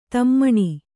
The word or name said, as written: ಧಿಮ್ಮನೆ